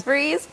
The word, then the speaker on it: Fries: And so if you look up my name, Kimberly Freeze, it's spelled like fries. a lot of people think it's fries, it's Freeze.